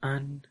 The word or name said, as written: an